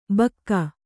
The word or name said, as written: ಭೋಕು